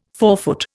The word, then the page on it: forefoot: Indian